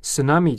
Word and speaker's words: tsunami: tsunami 这个词的意思呢是海啸，在这里呢 T 是不发音的。 tsunami 就可以了。